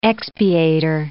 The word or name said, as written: expiator